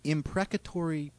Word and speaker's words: imprecatory: Instead of responding to Americans United's concern of illegal activity, Drake issued a plea to his supporters to join in imprecatory prayers. Am I pronouncing that right? – Sounds good. – Imprecatory.